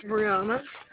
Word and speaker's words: Breunna: My name is Brianna. – Can you spell that for me? – B-R-E. – B-R-E, Bree? – B-R-E-U-N-N-A.